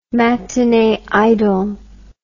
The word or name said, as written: matinee idol